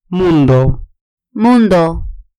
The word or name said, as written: mundo